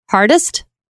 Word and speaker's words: Hardester: It's like Hardister. – Yes. – But like when we're in Europe or whatever, they cannot say it. They butcher it so bad. And I'm like, it's not that hard. You say hardest.